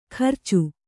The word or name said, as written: ಕರ್ಚು